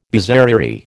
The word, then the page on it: bizarrerie: American English